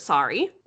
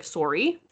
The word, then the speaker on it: sorry: A lot of the world pronounces it sorry, but we pronounce it almost like it has a double O, like sorry.